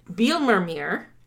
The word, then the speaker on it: Bijlmermeer: I learned, because I looked it up, that a J is pronounced as a Y in Dutch, because otherwise I was thinking it was Bijlmermeer. Bijlmermeer. – Bijlmermeer. – It's not. It's Bijlmermeer. Anyway.